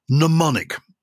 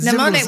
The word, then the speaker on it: mnemonic: It's a medieval mnemonic. Now you know what a mnemonic. Yeah, that good. mnemonic with the silent M. – That's it. Mnemonic.